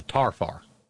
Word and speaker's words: tarfar: Yes, the correct Appalachian pronunciation of that is the tarfar. – Tarfar. – Tarfar. Well, T-A-R-F-A-R, one word, tarfar.